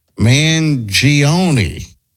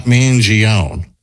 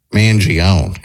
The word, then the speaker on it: Mangione: The name is Mangione, not Mangione. Do better, Rover. Okay, well, we're in America. We're not in Italy, so we pronounce it Mangione here.